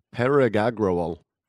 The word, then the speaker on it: Parag Agrawal: His name is Paraj Agrawal. Agrawal? – Parag Agrawal.